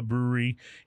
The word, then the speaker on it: Bruery: And then from a very influential brewery in Placentia, California, called The Brewery, but it's spelled B-R-U-E-R-Y.